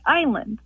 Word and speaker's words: island: Why is it island always spelled island, and why do we always pronounce it island? – Oh, what a good question. Why does island have an S in it, and why is it pronounced with a long I?